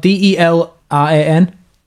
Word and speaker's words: Delran: It says we're a pop punk band from, I want to say it's Delran. It's spelled D E L R A N, Delran.